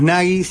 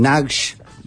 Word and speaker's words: Nagy: Nosotros decimos Nagy, se escribe N-A-G-Y. Algunos le llaman Nagy, ¿no? Cuando uno escucha los audios en inglés, por ejemplo, de la prensa occidental, decían Imre Nagy, ¿no? Porque esa G dice ahí, ¿no?